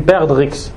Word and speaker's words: perdrix: Donc ils sont de la forme, la trace qu'il a dans le dos, elle ressemble à l'œuf d'une perdrix. En arabe, il a des on prononce pas le X. Perdrix.